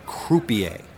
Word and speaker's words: croupier: "A crapier. Jesus, you're turning into Smitty. It's pronounced croupier."